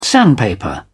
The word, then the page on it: sandpaper: British English